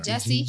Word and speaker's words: Jussie: I mean, Jussie. – Jussie, you said it right.